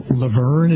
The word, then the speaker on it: Luverne: I believe it's Laverne, is that how it's pronounced? L-U-V-E-R-N-E? – Yes, it is.